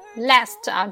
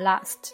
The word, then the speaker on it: last: 这里呢 last, 注意是美式发音。英音的时候我们会读成 last, 那么美音的时候我们读梅花音 last。